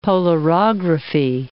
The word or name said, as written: polarography